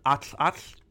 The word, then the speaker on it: Atlatl: And the Atlatl in that traditional pronunciation would be more of like an Atl, but for modern kind of sake, we call it an Atlatl. The Atlatl